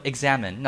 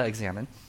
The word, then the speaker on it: examen: Examen. Now, the word examen is a Latin word, which means to examine. I know, we spell it differently, but it's pronounced the same way.